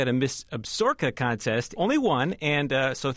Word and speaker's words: Absaroka: Well, that's what I thought, too. It's actually pronounced Absaroka, and it's the best.